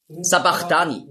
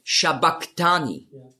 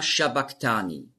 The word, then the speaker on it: sabachthani: Sabachthani lesen viele. Aber das heißt eigentlich auf Aramäisch ausgesprochen ist es Shabachthani. Das ist das CH, das Chi, ist im Griechischen als K ausgesprochen worden. Aber das S steht eben für den Sch-Laut. Und darum war die Aussprache ist Eli, Eli, Lama Shabbaktani.